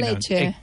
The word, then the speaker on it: Ecce: Escolta, ara que parlem tant d'aquest tema, com pronunciem Ecce Homo? A la llatina Ecce o a la medieval Ecce? – Ecce. – Bueno, perquè aquesta és la pronunciació llatina, però com que després es posa de moda a l'època medieval...